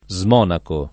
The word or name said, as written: smonacare